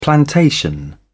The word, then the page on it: plantation: British English